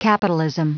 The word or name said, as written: capitalism